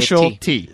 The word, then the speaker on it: specialty: Those actually are two different specialty. – Oh, you're right. – So they say speciality, we say specialty.